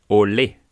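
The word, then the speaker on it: Olé: Allerdings ist die Betonung am E, Ole, das ist Spanisch.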